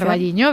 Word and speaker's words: O Carballiño: O Carballiño, no lo sé pronunciar. – O Carballiño, bien.